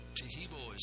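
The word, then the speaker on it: Taheebo: Tehibo is spelled T-A-H-E-E-B, like boy, O, then the word tea, and then the word club.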